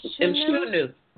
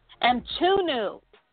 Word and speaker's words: Mchunu: How do you say his name? – Munch. Mchunu. Mchunu. – Mchunu. All right, mm-hmm.